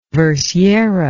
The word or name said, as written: versiera